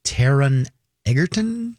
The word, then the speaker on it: Taron Egerton: Taron Egerton. – Correct. – Am I saying that right? – Yes.